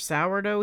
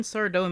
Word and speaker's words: Sardo: I mean, his name isn't spelled Sourdough, Keith. It's spelled S-A-R-D-O. And this is when Sardo makes his grand entrance by saying, that's Sardo, no Mr. accent on the dough.